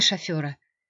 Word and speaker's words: шофер: Вызывай шофера!» Несмотря на свою тревогу, Женя не могла не улыбнуться. Слово «шофер» ее муж произносил с ударением на первый слог и утверждал, что именно так правильно – «шофера».